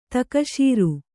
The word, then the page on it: ತಕಶೀರು: takaśiru